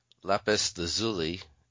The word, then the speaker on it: lapis lazuli: So today I wanted to talk to you about lapis lazuli. Am I saying that correctly? – Yes, you are.